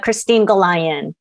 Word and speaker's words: Cristine Gollayan: How do you pronounce it? – That's close. Thanks. It's Cristine Gollayan.